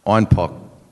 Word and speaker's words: Einbeck: In 1612, a brewer from Einbeck came all the way down to Munich to brew Einbecker beer, which they'd been shipping down there. The Bavarians kept asking for this Einbeck beer, which in their dialect they pronounced Einpock.